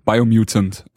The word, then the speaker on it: Biomutant: Die Rede ist vom Biomutant. – Mhm. Spricht man das so aus? Yes, genau. Biomutant.